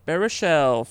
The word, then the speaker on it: Baruchel: Jay Baruchel finds himself, I don't know how to say the name, is that right? – Yep. – Baruchel finds himself in a dangerous position of befriending what he once thought his enemy, Ted Mosby's wife.